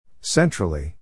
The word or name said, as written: centrally